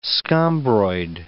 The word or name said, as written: scombroid